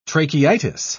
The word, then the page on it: tracheitis: British English